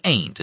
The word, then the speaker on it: aunt: You need to understand that in cold sassy, when the word aunt is followed by a name, it's pronounced ain't, as in Aunt Loma or Aunt Carrie.